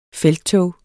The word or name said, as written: felttog